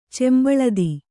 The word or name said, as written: ಚೆಂಬಳದಿ